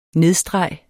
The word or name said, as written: nedstreg